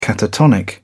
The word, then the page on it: catatonic: British English